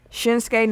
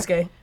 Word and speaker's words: Shinsuke: For SmackDown, you got Shane McMahon, Bobby Roode, Randy Orton, Shinsuke Nakamura. – Skinsuke. – He said Skinsuke because I typed it that way, but I said it properly.